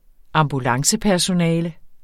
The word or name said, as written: ambulancepersonale